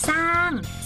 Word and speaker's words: สร้าง: คำที่เขียนควบสอเสือกับรอเรือจะออกเสียงแต่สอตัวเดียวไม่ออกเสียงตัวรออ๋อเช่นสร้าง